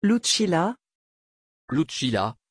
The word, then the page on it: Lucilla: French